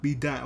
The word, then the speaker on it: B dot: They think it's Asian or some foolish like that, but it's pronounced like DOT. It's B dot. – B dot.